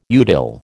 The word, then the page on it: utile: American English